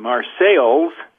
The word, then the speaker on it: Marseilles: It is, it is located in Marseilles, Illinois, spelled the same as Marseille, France, but pronounced differently.